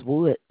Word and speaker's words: Wood: You say your last name is Woods. – Wood. W-O-O-D. No S.